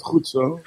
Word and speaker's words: goed zo: Can I hear the G again? Of goed zo. – Goed zo. – Oh, it's a soft G.